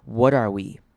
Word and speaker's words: what are: what are 我们连在一起的时候呢，我们会稍微把 t 弱化变成像是 the 的声音，所以我们会念成 what are we, what are we, what are we saying。